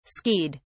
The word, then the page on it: skied: British English